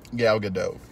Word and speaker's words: Gadot: Gal Gadot, yes. – Is that how you say it? – Mm-hmm.